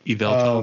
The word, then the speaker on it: Yveltal: Yveltal. – Um, Yveltal.